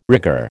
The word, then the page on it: rigger: American English